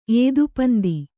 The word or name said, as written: ಏದುಪಂದಿ